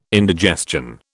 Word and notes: indigestion: British English

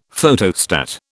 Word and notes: Photostat: British English

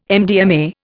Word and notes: MDMA: Indian

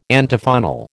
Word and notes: Antiphonal: American English

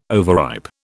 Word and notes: overripe: British English

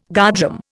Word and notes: guardroom: Indian

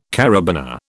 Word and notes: karabiner: British English

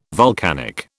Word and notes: vulcanic: British English

